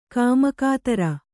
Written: ಕುಮ್ಮಕ್ಕುದಾರ

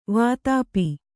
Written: ವಾತಾಪಿ

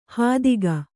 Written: ಹಾದಿಗ

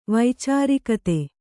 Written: ವೈಚಾರಿಕತೆ

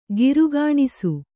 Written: ಕಾರ್ಗೆಣಸು

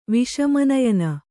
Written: ವಿಷಮನಯನ

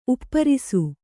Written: ಉಪ್ಪರಿಸು

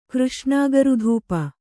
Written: ಕೃಷ್ಣಾಗರುಧೂಪ

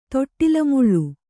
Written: ತೊಟ್ಟಿಲಮುಳ್ಳು

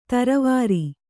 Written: ಥರಾವರಿ

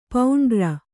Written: ಪಾಂಡುರ

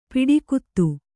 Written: ಪಿಡಿಕುತ್ತು

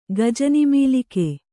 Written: ಗಜನಿಮೀಲಿಕೆ

